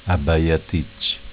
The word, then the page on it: Abaiaticc: Dialetto